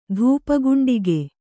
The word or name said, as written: ಧೂಪಗುಂಡಿಗೆ